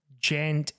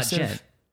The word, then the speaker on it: djent: Djent is like the word comes from Meshuggah because uh, I think it was uh, because uh, there's a fucking silent D in the beginning of the